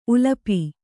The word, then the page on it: ಉಲಪಿ: ulapi